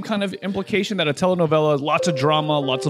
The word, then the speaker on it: telenovela: sounds a lot like a telenovela. Did I say that correctly? Is that the right word? – Yes, a Mexican telenovela.